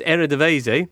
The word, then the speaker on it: Eredivisie: Posted in last week and said, when, or words to the effect, are we going to have some proper coverage of Holland's Eredivisie,